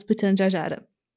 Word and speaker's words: Pitjantjatjara: Ayers, na verdade, é o nome dado a Uluru, que é tipo o lugar mais sagrado da vida pro povo local, os Pitjantjatjara. Eu pesquisei essa pronunciação, gente, eu sei como é que fala.